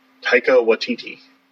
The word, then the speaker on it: Taika Waititi: Tiki Watiti. – Taika Waititi.